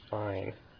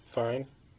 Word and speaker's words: Fiennes: The proper pronunciation of the name is Fine, I think. Mm-hmm. As in Rafe Fine. Didn't Ralph Fiennes pronounce his name Rafe Fine? – I believe that may well be correct.